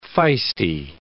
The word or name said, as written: feisty